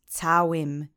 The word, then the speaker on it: całym: And the last word is całym. This means a whole, like W-H-O-L-E. It's a masculine singular object form of the noun. Let's say całym together three times. Accent is on the first syllable.